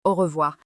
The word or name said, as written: Au revoir